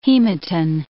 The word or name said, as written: hematin